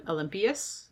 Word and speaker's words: Olympias: I'm thinking, so this, we're talking about Alexander's mother, Olympias? Is that her name? Did I pronounce that right? – Yeah, that's the name that she takes.